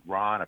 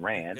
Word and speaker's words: Rand: I've heard Ron. I've heard Rand. I'm going Rand. – It's Rand. – Because I heard Mr. Pogue say Rand. – So yeah, it's Rand.